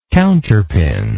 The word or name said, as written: counterpin